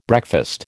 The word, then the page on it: breakfast: American English